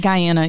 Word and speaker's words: Guyana: Okay, well, what was it like to be raised in Guyana? Is that how you say it? – Yeah, Guyana, yeah.